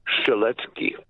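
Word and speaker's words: Strzelecki: Strelecki is actually pronounced Strelecki. – Strelecki. – Strelecki, because it is a Polish name.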